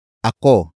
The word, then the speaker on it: ako: The next word is ako. Ako. Ako. Ako is spelled as A-K-O.